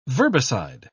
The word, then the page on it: verbicide: British English